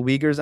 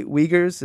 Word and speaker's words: Uyghur: Am I saying it right? Uyghurs? Is that how you say it? – Yeah, Uyghur. – But you can also say... – The Uyghur pronunciation is something more like Uyghur.